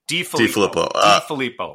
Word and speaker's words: DeFilippo: And you look at their offensive coordinator, John Filippo. – DeFilippo. There you go.